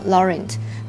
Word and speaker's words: Laurent: 这个 T 呢，如果后面没有一个这个 E 呢，就不发音。所以这个三罗号这个。 Laurent 虽然是 L A U R E N T 啊，如果用英文读法，那这个 R E N T 它不会读成 Laurent，那它是 Laurent 啊。